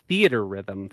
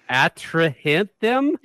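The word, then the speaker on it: Theatrhythm: That it's pronounced Theater Rhythm Final Fantasy, because I just saw the word Final Fantasy, and I was like, what the hell is this fantasy word? The Atrahinthem? What's the Atrahinthem?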